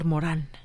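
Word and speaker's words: Morin: Hoy me llamó mucho la atención cuando anunciando el programa dijiste que estaría el doctor Edgar Morín y me emocioné. – Por Edgar Morán. Por, Sí, pronunciado en francés, pero escrito igualito.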